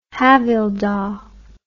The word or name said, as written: havildar